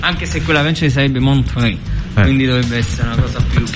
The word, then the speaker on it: Montreux: Sì, già pensare a un gruppo napoletano al Festival del Jazz, l'International Jazz Festival di Montreux, come si pronuncia? – Montreux. Con la R motion, è una grandissima.